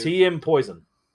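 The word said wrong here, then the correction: TeaMp0isoN: It's actually Team Poison. – Team Poison. Well, it's the way it's spelled.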